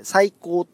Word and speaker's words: 3150: 3150 音楽院っていう名前最高って読む